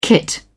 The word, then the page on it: kit: British English